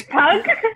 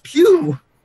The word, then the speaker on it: Pugh: It's not Pug. It's Pew.